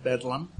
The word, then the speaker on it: Bethlem: You know, around 1930 or so, there was a little mental hospital you may have heard of in London called Bethlehem. Mm-hmm. That's spelled B-E-T-H-L-E-M, although the locals mostly called it Bedlam.